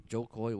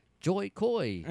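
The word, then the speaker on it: Jo Koy: But I didn't know who Joe Coy was, and I actually butchered his name. I said, y'all give it up for Joy Coy. I fucked his name up, and then he got mad at me. And like, oh, my bad, bro. I don't know why I thought your name was Joy. It just happens to rhyme with Coy.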